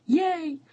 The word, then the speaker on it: Yeh: Armed with a smile as big as Julia Roberts' cookbook, author and host Molly Yeh, her last name is literally pronounced Yeh, welcomes viewers into her North Dakota home with easy-to-make comfort food.